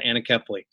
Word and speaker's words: anencephaly: It wasn't until after the death of our first child from a condition called anencephaly, or some pronounce it anencephaly.